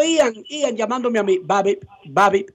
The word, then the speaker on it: BABIP: B-A-B-I-P, Babi.